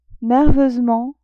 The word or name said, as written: nerveusement